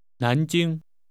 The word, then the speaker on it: 南京: 南京。 – 我得出差去南京。南京。 – This name is pronounced with rising and high level tones.